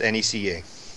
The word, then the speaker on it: NECA: And you know, with the NECA's, with NECA, NECA, however you want to say it, what is the definitive pronunciation of that company's name? I would like to know this. – If you work there, it's NECA.